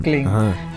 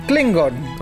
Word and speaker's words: Klingon: Se escribe con K de Cligón. No, Klingón. Ah, cabrón, ¿cómo Klingon. – Klingon.